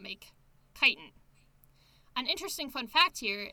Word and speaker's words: chitin: Another distinction between mushrooms and plants is that mushrooms make their cell walls out of chitin. It's pronounced chitin. – Yes. – It really looks like it should be kitten.